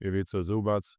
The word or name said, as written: Ivica Zubac